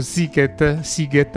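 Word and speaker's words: Sziget: E abbiamo contattato uno del, insomma, del, delle, degli organizzatori del, dello Siget, anzi si pronuncia così, ce lo spiegherà appunto Ettore Fogliero.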